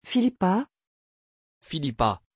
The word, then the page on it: phillipa: French